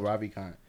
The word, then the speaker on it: Ravikant: Naval Ravikant.